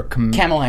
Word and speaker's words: Kamala: And our new, I don't know how to pronounce her, is it Kamala? Or Kamala Harris. – Kamala.